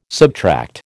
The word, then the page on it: subtract: American English